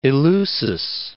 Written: Eleusis

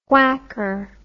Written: whacker

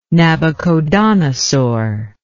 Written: Nabuchodonosor

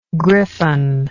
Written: gryphon

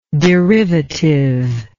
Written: derivativeness

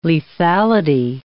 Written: lethality

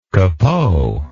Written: capot

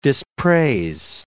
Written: dispraise

